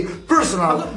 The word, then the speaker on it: PRSNL: E comunque si legge così, personal.